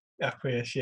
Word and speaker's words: Acquiesce: How do you pronounce it? Acquiesce? – Acquiesce, yeah.